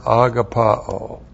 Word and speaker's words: agapao: And the key word here is the verb agapao. A-G-A-P-A long O. Agapao.